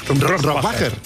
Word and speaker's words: Rohrbacher: Rohrbacher.